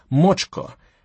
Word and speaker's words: macska: The spelling, M-A-C-S-K-A, the pronunciation, Mochka, how you'd use it in a sentence, 1,000 images of each Mochka you've ever met.